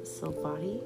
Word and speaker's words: Silvati: You can find me on Facebook as Amanda Silvati. Last name is spelled S-I-L-V-A-T-I.